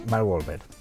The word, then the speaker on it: Mark Wahlberg: está casi confirmado que Mark Wahlberg, ¿lo he dicho bien? Mark Wahlberg. Eso.